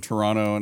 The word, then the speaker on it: Toronto: I don't know why I just pronounced the second T, probably because I said it slow. – Toronto? Sounds like an American. Toronto. – Yeah. – It's Toronto.